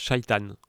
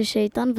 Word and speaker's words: shaitan: Je dirais qu'il se prononce shaitan. C'est moi qui l'ai inventé, donc je peux l'appeler comme je veux. Hein. – Moi j'ai dit que ça se disait shaitan parce que aussi en arabe ça se dit shaitan en fait.